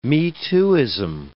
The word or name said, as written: me-tooism